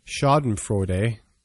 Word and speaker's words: Schadenfreude: Was my pronunciation okay? – Yeah. And I mean, I'm certainly not a guru on pronunciation, but yeah, Schadenfreude is a corollary of envy.